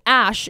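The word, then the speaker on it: Æ: The AE is pronounced as Ash in the Scandinavian alphabet.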